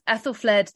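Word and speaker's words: Æthelflæd: She's written a biography of Ethelfled the Warrior King. Did I pronounce the name correctly, Joanna? – You did.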